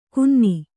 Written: ಕುನ್ನಿ

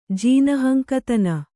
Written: ಜೀನಹಂಕತನ

